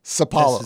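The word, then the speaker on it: São Paulo: Should note for anybody that's looking up that song online that you have titled it not as the town is spelled, Sao Paulo, but more as how James Brown said it, Cipollo.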